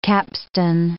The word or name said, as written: capstan